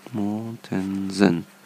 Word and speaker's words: Mortensen: Naja, ihr wisst schon, ähm, alle DVDs vom Lachsfischen in Island von Henrik Mortensen. Ich hoffe, ich habe das richtig ausgesprochen. Mortensen. Ja, genau.